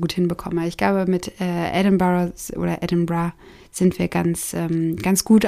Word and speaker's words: Edinburgh: Allerdings hören es die Schotten tatsächlich nicht so gerne, wenn man von Edinburgh spricht. Sie selbst nennen es eigentlich Edinburgh. Und die geläufige Kurzform, wie es aber eigentlich die Ortsansässigen aussprechen, ist Edinburgh. Also da wird dieses Borough kurz gemacht. Eigentlich wird dieses Bra noch fast das R ein bisschen gerollt.